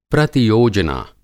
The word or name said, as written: प्रतियोजना